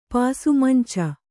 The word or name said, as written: ಪಾಸುಮಂಚ